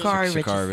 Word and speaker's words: Sha'Carri: Sha'Carri? – Sha'Carri Richardson? – Sha'Carri Richardson.